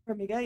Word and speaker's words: Farmiga: I feel like this one's like the same time-ish as what's the one with Vera Farmiga? Is that how you say her name? – Farmiga, yeah, that's The Conjuring.